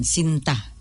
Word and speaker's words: sinta: Just that we pronounce the vowels harder, like makita ka and sinta, not silent N, sinta.